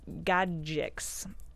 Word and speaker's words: Gajdic: Peter Gajdic. G-A-J-D-I-C-S. Gajdic. That's probably how you, kind of how you say it.